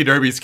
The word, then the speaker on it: Derby: And according to the commercials NBC keeps showing me, the Kentucky Derby's coming up on NBC. – And also not to be confused with Derby, the way that the Brits pronounce it, which is Derby Day, but spelled Derby.